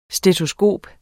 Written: stetoskop

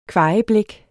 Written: kvajeblæk

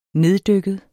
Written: neddykket

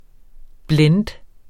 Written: blend

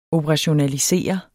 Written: operationalisere

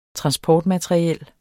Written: transportmateriel

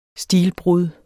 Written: stilbrud

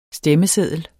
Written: stemmeseddel

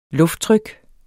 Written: lufttryk

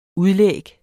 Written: udlæg

